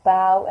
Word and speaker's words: bough: I take it you already know of tough and bow and cough and dough. Of those words, bow is probably the least well known. Bow, B-O-U-G-H, is pronounced the same as the verb bow, B-O-W. A bough, B-O-U-G-H, is a branch of a tree.